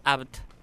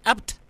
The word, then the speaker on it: Abt: Qué bien pronunciado mi apellido. – Ah, bueno. ¿Por qué? – Que no es poco. – ¿Pasa que no te dicen así? Abt, así.